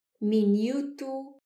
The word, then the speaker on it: minuto: Costumo ouvir minuto, minuto, e todas estas palavras são influenciadas pela língua inglesa, mas não têm a pronúncia correta portuguesa. A pronúncia correta é minuto, minuto.